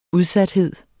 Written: udsathed

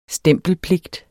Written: stempelpligt

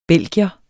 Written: belgier

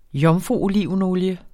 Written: jomfruolivenolie

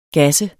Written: GASSEDE

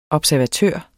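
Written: observatør

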